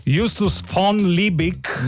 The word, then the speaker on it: Justus von Liebig: Nel 1835 il chimico tedesco Just von Liebig, si pronuncerà così? Aiuto, voglio un aiuto dal pubblico. Vai, aiutami tu. – Justus von Liebig.